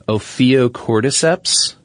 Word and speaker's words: Ophiocordyceps: Well, we've got a zombie fungus here, and you seem familiar with this when we were first talking about this, but I believe it's pronounced Ophiocordyceps. – Yeah. – Is that correct? – Cordyceps.